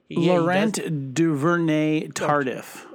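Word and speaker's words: Laurent Duvernay-Tardif: They had offensive linemen, and I know I'm going to get this name wrong because it's like Laurent Nani Tardif, who happens to be the only official doctor in the NFL as a player. – Laurent Duvernay Tardif. – Tardif, that's it, Duvernay.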